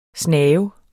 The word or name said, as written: snave